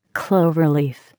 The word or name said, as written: cloverleaf